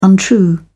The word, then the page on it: untrue: British English